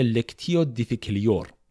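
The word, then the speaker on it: lectio difficilior: اما تلفظ لاتینش میشه لکتیو دیفیکلیور بخوایم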